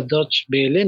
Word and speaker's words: Deutsche Welle: cadena alemana que creo que se pronuncia Deutsche Welle, no estoy seguro, no hablo alemán, pero bueno, la, conocemos porque el icono es DW.